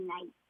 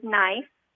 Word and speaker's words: knife: Yeah, I said knife. – So gave the word knife. – Oh, that's a good one, starting with a silent K, excellent.